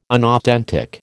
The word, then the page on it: unauthentic: American English